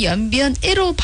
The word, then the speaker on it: 연변의: 여기에서 발음에서 연변의 자랑, 연변이 아니고 연변애로 발음해 주시기 바랍니다.